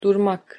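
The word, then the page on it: durmak: Turkish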